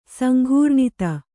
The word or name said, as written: ಸಂಕೀರ್ಣತೆ